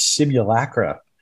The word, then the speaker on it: simulacra: Oh, we've got a pronunciation on this too. It's simulacra.